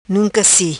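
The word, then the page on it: Nunca Si: patua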